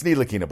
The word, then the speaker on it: Ntilikina: Instead of using the, it's Ntilikina, buddy. But he said it incorrectly.